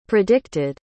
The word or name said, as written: predicted